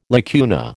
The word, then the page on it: lacuna: American English